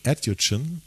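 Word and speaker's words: Edugyan: Beruht ja auf einem Booker Prize-nominierten Roman von einer schwarzen kanadischen Schriftstellerin, Essie Adjutschen, ich habe mir extra aufgeschrieben, wie es ausgesprochen wird, Adjutschen.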